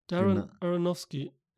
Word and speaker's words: Darren Aronofsky: Darren Aronofsky. – Darren Aronofsky? Mhm. Darren Aronofsky.